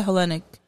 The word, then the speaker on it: Hellenic: Say the Hellenic. – Yeah. – Am I pronouncing that correctly? – You are. – Because it's like Helena, right? – Yep.